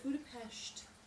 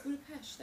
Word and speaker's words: Budapest: Is that how you say it? It's Budapest. – I don't like that. – It's Budapest. That's how they say it in Hungary. Budapest.